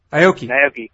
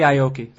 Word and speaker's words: Aoki: Aoki. – However you pronounce it. – Aoki. Yeah, Nori Aoki. That's actually an easy one, even for the for a totally anglicized person to pronounce. It's Nori Aoki.